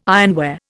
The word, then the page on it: ironware: Indian